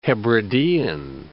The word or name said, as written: Hebridean